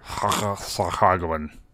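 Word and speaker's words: Sahuagin: And so you just like kind of get to do other stuff, like talk to the Sahaguin. How do you say those dude's name? – I think you got to get some phlegm in there. – Sahaguin. – Yeah, exactly.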